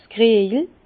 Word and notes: s' Kriejel: Bas-Rhin; Herrlisheim

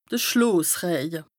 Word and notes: de Schloosrëje: Bas-Rhin; Strasbourg

